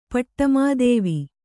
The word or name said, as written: ಪಟ್ಟಮಾದೇವಿ